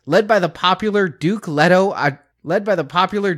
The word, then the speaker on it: Leto: It's Leto if you really want to get geeky, but it's yeah.